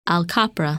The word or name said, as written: Al Kaprah